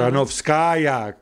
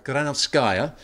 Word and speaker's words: Granovskaya: Granovskaya. – Granovskaya.